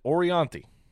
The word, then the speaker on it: Orianthi: Her name is Orianti. Born in 1985. – How the fuck do you even spell that? – O-R-I-A-N-T-H-I.